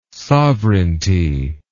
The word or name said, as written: sovereignty